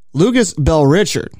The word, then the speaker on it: Lucas Bell Richard: I got to make sure I'm pronouncing this correctly. Lucas Bell Richard. – Yep. – That's how you pronounce it. All right. It's like two names in one.